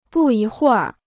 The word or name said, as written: 不一会儿